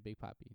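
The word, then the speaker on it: Big Papi: Yeah, Big Poppy.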